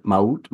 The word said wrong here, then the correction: Ma'at: ma'at ma'at ma'at yes